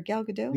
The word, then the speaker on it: Gadot: You said it right the first time. Gal Gadot. – Okay. I you know, it gets in my head when people like adamantly insist on pronouncing the T. And I'm like, I don't think that's right.